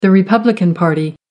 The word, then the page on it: republican party: American English